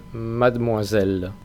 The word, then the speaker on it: mademoiselle: Y señorita se dice mademoiselle. Mademoiselle. La E de la sílaba interior, después de la D, esa E normalmente no se pronuncia.